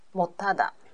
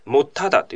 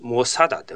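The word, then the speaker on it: 못하다: 못 하 다。 – これはもっと肌がついてるわけですね。もっただというふうに発音します。もさだではないですね。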